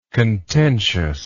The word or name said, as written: contentiousness